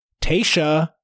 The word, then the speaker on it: Tayshia: Tayshia, which is how it's pronounced.